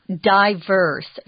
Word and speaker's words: diverse: Diverse is also pronounced diverse. You'll hear both pronunciations. They're both correct.